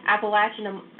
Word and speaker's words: Appalachian: Appalachian Anomaly.